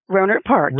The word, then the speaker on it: Rohnert Park: Rohnert Park? – Rohnert Park.